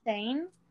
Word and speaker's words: Thain: Is that the correct way to pronounce that? – I'm not T-H-A-I-N, Thane? – Yeah.